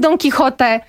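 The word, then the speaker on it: Don Quijote: Rzeczywiście w tradycji polskiej było tak, że tekst dzieła Don Quixota Miguela de Cervantesa trafił do nas z Francji i stąd my byliśmy przyzwyczajeni, żeby używać wymowy francuskiej. Jednakowoż bo oryginał powstał w języku hiszpańskim, więc my jako iberyści cały czas poprawiamy wszystkich, że to jednak jest Don Quixote.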